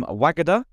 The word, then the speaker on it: Wagada: And on the podcast today, we've got Cheryl from Wagada.